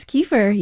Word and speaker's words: kefir: And then the one I asked you about the pronunciation of this, some people call it kefir. – Yes, kefir.